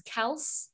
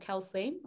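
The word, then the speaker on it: Kelce: Do I go Travis Kels? not Kelsey, Weird nice.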